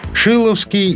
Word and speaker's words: Шиловский: Шиловский из столбовых дворян. Ударение в фамилии падает на первый слог.